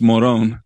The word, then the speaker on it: Morón: It's spelled Moron, but in Spanish it's Morón, in the Paracas area, actually in the Ica area, the Pisco area, rather.